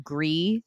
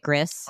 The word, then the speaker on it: Gris: But I don't know if you ever played the game Gris. It's G-R-I-S. So some people will pronounce it Gris.